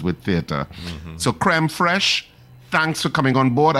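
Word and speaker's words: fraîche: And in the Chaguanas area, Creme Fresh, and it's pronounced, it's spelled F-R-A-I-C-H-E, Creme Fresh at the Brentwood Mall in Chaguanas.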